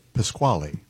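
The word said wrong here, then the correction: Pachelbel: By Pacabell. – You pronounce it Pasquale? – No.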